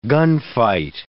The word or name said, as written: gunfight